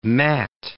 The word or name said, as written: Matt